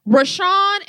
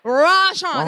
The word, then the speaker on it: Raashaun: R-A-A-S-H-A-U-N. He's one of us. – Wait. It's two A's back to back? – Yeah. Rashawn. They wanted to know it's like, rah.